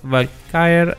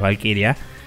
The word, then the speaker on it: Valkyrie: Eh, la siguiente noticia que tenemos es que el Yves Valkyrie o Valkyrie o como sea, nunca me sale pronunciar esta palabra así, porque no es Valkyria.